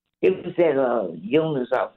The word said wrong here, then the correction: Euonymus: I can't pronounce it. Euonymus or something. – Oh, Euonymus?